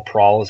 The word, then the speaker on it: Prahl: At Better Call Prawl is where you can find me. My last name is spelled P-R-A-H-L.